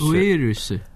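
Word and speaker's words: Virus (ウイルス): It's so obviously they don't have a V sound in Japanese. They use a B. But for some reason they've been really annoying, and it's pronounced it with a U instead of a V. So it's virus. Virus. – Virus. – Like U-I-R-U-S-U. Virus.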